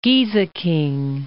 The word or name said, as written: Gieseking